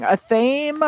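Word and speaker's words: athame: And I'm pronouncing this probably wrong. A thame? – I think you got it right.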